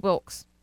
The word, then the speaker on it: Wilkes: Hold on, go back, make sure I'm saying his name right. Steve Wilkes. – Steve Wilkes.